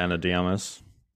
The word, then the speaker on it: Ana de Armas: Ana de Armas. – Yeah, Ana de Armas.